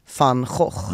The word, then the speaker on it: van Gogh: Vi ska börja med en tavla som heter De Pastori in Neunen det är en tidig eh, tavla av den holländska superkändisen van Gogh, eller som jag som talar holländska uttalar det, van Gogh.